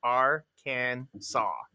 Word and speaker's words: Arkansas: Arkansas is legally supposed to be pronounced Arkansas.